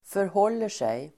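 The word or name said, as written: förhåller sig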